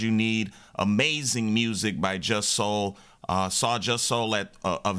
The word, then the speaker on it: Jussol: Chicago, that last song you heard by Just Soul, J-U-S-S-O-L,